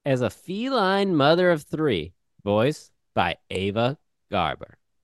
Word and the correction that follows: Gabor: Wait, no, that's not how you say that. – Gabor, G A B O R. How do you say her name? Gabor. Ava Gabor.